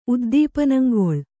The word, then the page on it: ಉದ್ದೀಪನಂಗೊಳ್: Kannada